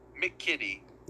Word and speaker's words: McKitty: And if you need a tight end, I need you out there looking for Chargers number 88, Trey McKitty. – It's a sweet name. – Ooh. – Trey McKitty. Kitty spelled like the kitty cat. Kitty cat.